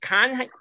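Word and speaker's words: Canha: And how do you say that? – Kanya. – So the H is silent.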